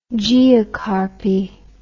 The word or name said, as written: geocarpy